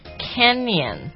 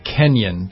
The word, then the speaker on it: Canyon: The Grand Canyon. – Canyon. C-A-N-Y-O-N. – Yeah, not Kenyan.